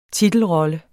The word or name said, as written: titelrolle